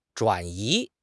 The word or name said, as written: 转移